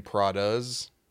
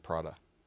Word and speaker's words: Prada: You've been saying Prada's, but it's Prada.